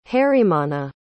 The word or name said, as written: harimanna